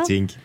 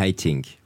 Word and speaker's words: Haitink: On rappelle votre album Schumann qui vient de sortir chez Warner Classic avec Bertrand Emtik, Martha Argerich et Renaud Capuçon, c'est ça? – Haitink. – Oui, redites ça. Le prononcé. – Bernard Haitink.